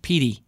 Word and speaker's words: Pedee: But it was spelled P-E-D-E-E. You went with? – Pede, I think. – Right, but I think we've received this mailbag item, and I think Petey is pointing out it's just pronounced Petey.